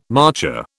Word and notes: marcher: British English

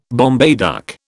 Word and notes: Bombay duck: British English

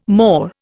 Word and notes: more: Indian

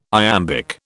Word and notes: iambic: British English

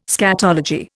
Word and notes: scatology: Indian

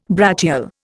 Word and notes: brachial: Indian

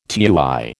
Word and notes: tui: American English

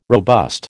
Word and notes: robust: American English

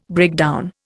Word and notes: breakdown: Indian

